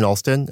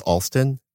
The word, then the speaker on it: Alston: I'm Brian Alston, and my name is spelled B-R-Y-A-N. And my last name is Alston, A-L-S-T as in train, O-N.